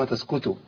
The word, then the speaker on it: تسقطوا: وفي حاجه في كذا حاجه حضرتك وضحتها برضك وما تسقطوا قاف تنطق كاف وما تسقطوا ده تسكتوا دي مشكله قوي كمان الطاء تبقى تاء كمان يبقى تسكتوا يبقى احنا كده غيرنا المعنى خالص.